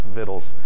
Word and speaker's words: victuals: That word really is, by the way, pronounced victuals. Every time we read it, people laugh and say, that really is the way that word, I know it's spelled victuals. Look it up in the dictionary. It's pronounced victuals, okay?